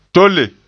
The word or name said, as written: tole